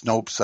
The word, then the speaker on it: Snopes: Snoops or Snopes or however it's pronounced. Snopes, I think, is the way it's usually pronounced.